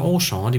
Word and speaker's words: Auchan: Okay, auf jeden Fall hat Auchan, oder wie die Luxemburger sagen, Auchan, die betonen ja tendenziell mhm. eher die erste mhm. Silbe, mhm.